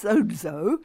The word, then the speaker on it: sozo: And it's actually pronounced sozo, and although spelled S-O-Z-O.